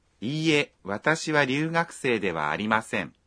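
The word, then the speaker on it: Iie (いいえ): Khi nói iie, các bạn phát âm chữ i dài ra một nhịp.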